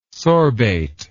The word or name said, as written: sorbate